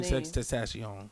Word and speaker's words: XXXTentacion: XXXTentacion. – I thought the X's was silent. – Nah, it's not Tentacion.